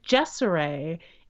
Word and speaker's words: Jeseray: Her name is Jessaray. It's spelled J-E-S-E-R-A-Y. – Oh, I like that. – Yeah, as you can hear, it kind of looks like Jersey, but it rhymes with Desiree.